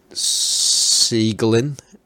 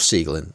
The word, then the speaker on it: Siegelin: And that's S-I-E-G-E-L-I-N. – Oh, I can't do that like that. – Yeah, that's okay. Well, I'm going to go with Siegelin. I'm going to go with that. Christoph Siegelin.